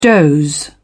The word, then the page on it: doze: British English